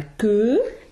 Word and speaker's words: queues: Leur courte veste de soie, leur longue robe à queue. – Exactly, so we have Q-U-E-U-E-S. And that's what sound again? – Que. E. – Yeah, so it's two letters phonetically, it's about 100 in French, right?